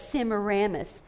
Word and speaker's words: Semiramis: I used to say it wrong, and somebody asked me if I was saying Sierra Mist. So I learned since then that it's Semiramis.